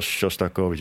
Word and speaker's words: Shostakovich: ja, Shostakovich. Ik heb het ooit aan een Rus gevraagd en die zei... je moet het heel egaal uitspreken, Shostakovich.